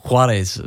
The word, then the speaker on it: Juarez: Use that and stick a J in the front and you'll get it. – Suarez. – No. – Suarez. – You need to lose the S. – Right. – I don't mean. – How do you say it? – That's him. Well, Juarez. – Juarez, right, okay.